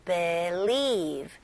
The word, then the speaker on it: believe: be, 그 다음에 두 번째 음절에는 leave, e 발음으로 believe, believe, believe, believe. Very good. 강세가 어디에 들어갔어요? 네, 첫 번째 음절보다는 두 번째 음절에 들어가죠.